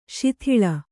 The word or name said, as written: ಶೀತಾಳ